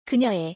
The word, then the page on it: 그녀의: korean